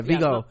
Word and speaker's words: Viggo: And that Viggy, V-I-G-G-O? – Viggo Mortensen. Yeah, Viggo.